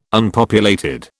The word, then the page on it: Unpopulated: British English